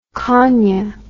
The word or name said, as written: kania